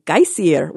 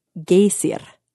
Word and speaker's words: Geysir: Yeah, geyser, they were first named for geysir. Wait, I looked up the pronunciation. Hang on, I have to hear it again. Geysir. Geysir, which is spelled G-E-Y-S-I-R.